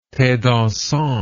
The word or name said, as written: thedansant